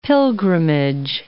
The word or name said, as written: pilgrimage